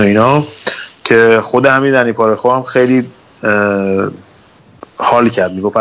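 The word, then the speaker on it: Parejo: پاره رو نگه داشتن پارخو دیگه اسمش فکر کنم تلفظش درستش پارخو پارخو درست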